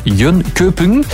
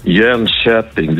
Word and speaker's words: Jönköping: Het is niet Jönköping, hè? Dat is totaal verkeerd. Hè? Wat moeten we dan wel zeggen? – Ja, het is eigenlijk Jönköping. Jönköping, dus de K voor de omlaut wordt heel zacht uitgesproken in Zweden.